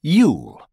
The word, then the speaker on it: Yule: Yule. – An alternative or ironic way to say Christmas. Rhymes with cool, as in have a cool Yule.